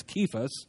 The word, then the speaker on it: Cephas: it's actually got a K sound. It's Cephas.